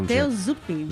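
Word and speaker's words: Matteo Zuppi: Mateo Zuppi.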